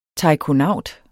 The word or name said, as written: taikonaut